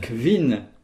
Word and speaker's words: kvin: Kvin. K-V-I-N. 6 se dit Six. – Ses. – Ses. – S-E-S.